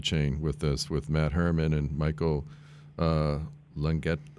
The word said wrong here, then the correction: Lagante: I don't know if I pronounced his name right. Was it Latane? And Jerry? – Yeah, Lagante. Yeah, Lagante, sure.